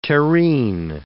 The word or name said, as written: terrene